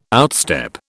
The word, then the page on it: outstep: British English